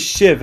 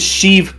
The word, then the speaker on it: Sheev: His name is Shiv Palpatine. – Is that how you pronounce it? Shiv? – Well, it's spelled S-H-E-E-V, so... – I thought it was always pronounced Shiv.